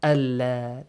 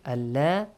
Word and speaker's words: Allah: The word Allah is pronounced heavily since there is the fatha sound before it. And if there was a dhamma, the same it will be pronounced. But if there is a kasra, it will be pronounced lightly. Allah, this way. Allah, Allah, Allah, Allah. Two different ways.